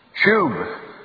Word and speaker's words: shub: They use a word out of the Old Testament language, shub. This is a vowel sound, you, shub. Sh Shub is the word in the Old Testament most often translated to repent.